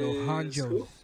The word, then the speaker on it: Dos Anjos: Dohanjos. That's how you say it.